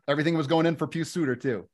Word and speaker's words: Pius Suter: Pius Suter. – Pius. Okay. – Pius.